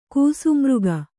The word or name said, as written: ಕೂಸುಮೃಗ